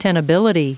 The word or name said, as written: tenability